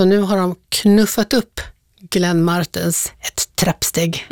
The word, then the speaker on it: Glenn Martens: Glenn Martens med betoning på R-et. Vem är det? – Ja, det är inte Glenn Martin. Utan Glenn Martens.